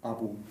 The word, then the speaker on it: ABO: Aber es ist in Avivo relativ schön dokumentiert, von daher bietet es sich eigentlich an, diesen Artikel zur Hauptversammlung der Abowind, was sagt man da, Abowind, Abowind, was, wie wird das ausgesprochen? – Abo.